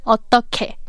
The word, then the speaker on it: 어떻게: The final consonant of the second syllable is 히읗, 어떻, and the last syllable is 게, 어떻게, but it's pronounced as 어떻게. Because when 히읗 meets 기억, it's combined and pronounced as 기억, so 어떻게.